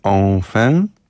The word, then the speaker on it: Enfin: Okazuje się, że tytuł płyty Polnareffa czyta się Enfin.